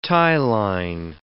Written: tie-line

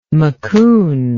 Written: macoun

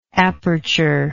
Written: apertured

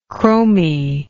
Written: chromy

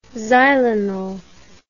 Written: xylenol

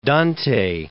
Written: Dante

